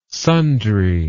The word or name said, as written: sundry